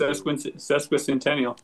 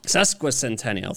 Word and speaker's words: sesquicentennial: Am I saying that right? Yeah. 150 years is sesquicentennial. – Sesquicentennial.